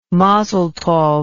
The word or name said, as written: mazel tov